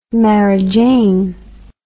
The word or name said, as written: marijane